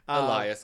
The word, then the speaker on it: Elias: How do you say this name? Elias. – Elias. – Fine. – Elias, because it's Eli for short.